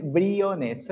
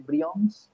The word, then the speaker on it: Briones: Incluso acá en Colombia, y, y, yo luzco como latino, la gente me dice Brions, Briones.